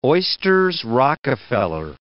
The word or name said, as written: oysters Rockefeller